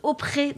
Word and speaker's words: Opre: Ah, et ça se prononce Opre, d'accord.